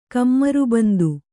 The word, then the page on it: ಕಮರಬಂದು: kamarabandu